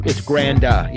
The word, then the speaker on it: Granda: It's Granda, yeah. Granda. It's Grand with an A at the end.